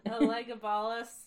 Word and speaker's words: Elagabalus: Elagabalus? – Elagabalus, yeah.